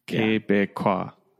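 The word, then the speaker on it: Québécois: If you think about it with K's, try and pronounce it like as if the Q's are K's. It's a good way to do it. – Quebecois.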